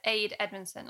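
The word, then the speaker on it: Ade Edmondson: And am I saying this right? Is it Aid Edmondson, like as in Jennifer Saunders's husband? – Oh, yeah. Yeah.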